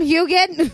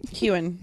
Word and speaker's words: Heughan: Show us your back, Sam Heughan. – Heughan. – Heughan. – I would say it like that first, and then I heard him say it, and I was like, oh, I've been saying it wrong.